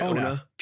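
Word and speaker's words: Onah: Am I pronouncing his last name right? – Ona. Okay, cool. Julius Ona.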